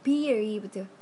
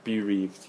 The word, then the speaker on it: bereaved: The bereaved. – Bereaved, yeah. – It's kind of L 발음은 없어요. – Right, L 없고 그냥 R, the bereaved.